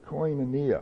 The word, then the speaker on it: koinonia: Now the Greek word for fellowship is koneos. Is that how you pronounce it? Koneos. There's koinonia, that's it. That's a better pronunciation, koinonia.